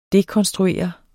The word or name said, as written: dekonstruere